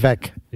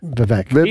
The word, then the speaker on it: Vivek: Vivek. – Yeah. – Vivek.